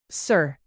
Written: sir

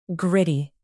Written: gritty

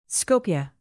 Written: skopje

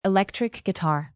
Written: electric guitar

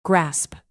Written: grasp

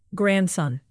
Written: grandson

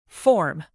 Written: form

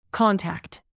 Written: contact

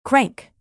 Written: crank

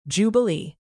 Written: jubilee

